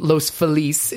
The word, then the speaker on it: Los Feliz: But I'm doing it at Rockwell, which is in Los Feliz is how they pronounce it.